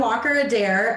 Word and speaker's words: Walker Adair: We're here with our friend Walker Adair.